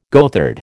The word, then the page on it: goatherd: American English